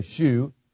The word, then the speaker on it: shew: Now that word show, S-H-E-W, is pronounced show. A lot of times people read it and say shoe. That's what Ed Sullivan used to say. And it could be pronounced shoe or show, and it just means what you see, just like anything else that shows.